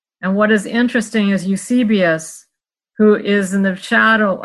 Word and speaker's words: Eusebius: That's Eusebius.